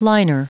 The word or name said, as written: liner